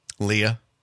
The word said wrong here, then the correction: Leia: Leia. – Sorry. – That's how you say it.